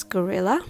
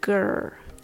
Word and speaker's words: gorilla: How do you pronounce gorilla? Well, you don't have to say gorilla, like telling someone to go somewhere. The first syllable is pronounced gur, gur. The next two syllables has the r and the l sound put together, so it can be tricky. Er, uh, L. Let's slowly put them together. Gorilla, gorilla, gorilla. The rhythm goes to tada, gorilla.